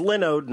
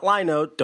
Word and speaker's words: Linode: Now, remember, it's Linode, not Linode. Don't mispronounce it.